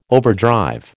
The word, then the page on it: overdrive: American English